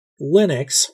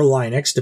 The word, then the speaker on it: Linux: A lot of us use Windows and Mac, but Linux or Linux, depending on how you say it, is used really for most internet operations, servers, and that type of thing in one form or another.